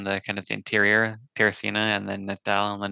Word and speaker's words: Teresina: Brazil, Teresina. Yeah. Did I say it right? – Yeah, Brazil had 2 more temples, one in Teresina, one in Natal.